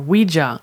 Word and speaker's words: Ouija: It's called uh, Ouija, Ouija board.